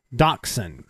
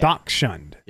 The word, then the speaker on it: Dachshund: I think you just sound make it like a K. – Dachshund. Yeah. Dachshund.